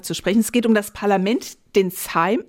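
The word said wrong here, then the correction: Sejm: nee, Same wird er ausgesprochen, den Same, ne?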